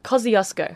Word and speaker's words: Kosciuszko: Kosciuszko.